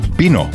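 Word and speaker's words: vino: Hele, Španělé, oni to B úplně nevyslovují. Třeba, když máš slovo víno, který všichni znají, tak spíše to bino.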